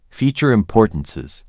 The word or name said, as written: feature importances